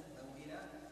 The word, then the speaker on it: موئلا: على قصر الواو الأولى التي بعد في الموؤودة في سورة التكوير، وقصر واو موئلا في سورة الكهف، والمقصود بقصر الواو في هذه الكلمات الثلاث سوءات الموؤودة موئلا النطق بواو من غير مد مطلقة مثل النطق بواو فوق.